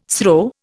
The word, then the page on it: SRO: Indian